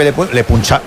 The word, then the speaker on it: Le Puncheur: Le Puncher. ¿Cómo se escribe Le Puncheur. – Le Puncheur. – Eso es.